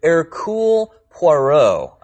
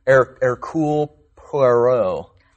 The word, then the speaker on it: Hercule Poirot: She chose a detective named Hercule Poirot, I believe is how you say that. It sounds very French, Hercule Poirot.